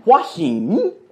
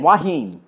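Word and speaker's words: Hua Hin: Option number 1, Thailand, Huahin, that's how they say it, Huahin, H-U-A space H-I-N, Huahin, that's how the foreigners say it.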